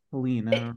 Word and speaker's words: Helene: I don't know why I thought it was Helene.